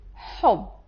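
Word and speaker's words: hub (حب): Love in Arabic, it is hub.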